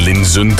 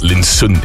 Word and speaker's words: Linsen: En bij mij is dat Joren Linsen, terwijl het Linsen is.